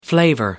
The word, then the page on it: flavour: American English